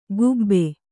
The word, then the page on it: ಕೂಬೆ: Kannada